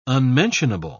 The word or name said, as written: unmentionable